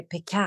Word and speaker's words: Picard: Vi måste bara lära oss hur vi uttalar det. Är det Picard? – Picard.